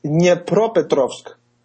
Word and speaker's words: Dnipropetrovsk: Kolomoisky was even appointed governor of Dnipropetrovsk. – Good job. I was hoping to get out of the Russian pronunciation today. That was great. – I hope that's correct. Dnipropetrovsk.